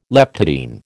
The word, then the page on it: leptotene: American English